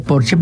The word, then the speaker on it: Porsche: O decir que tengo un Porsche en lugar de decir tengo un Porsche, porque en alemán Porsche se dice Porsche porque todas las S al final se pronuncian, como Marlene Dietrich, por ejemplo, que todo el mundo dice Marlene, pero es Marlene.